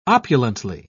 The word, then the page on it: opulently: British English